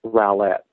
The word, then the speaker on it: Rowlett: Chad, I couldn't help but call in to help you pronounce that city in Texas. It's Rowlett.